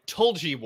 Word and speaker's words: Tulgey: It's or sorry, Tulgey Wood. I might have the pronunciation wrong. It's T-U-L-G-E-Y.